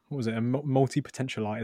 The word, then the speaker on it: multipotentialite: Speaking as a multi-potentialite, is that how you say it? – Yeah, that's how you say it, yeah.